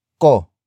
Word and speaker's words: ko: The answer is introduced by the next word, ko ko. Ko. Ko is spelled as K O.